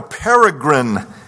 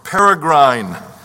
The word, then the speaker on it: peregrine: We should bear in mind that we are, here's his title, that we are in a peregrine condition, or peregrine. Both are acceptable pronunciations.